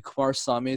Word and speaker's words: Kumarasamy: But my name is spelled B-R-E. N D E N and Kumarasamy is K U M A R A S A M Y.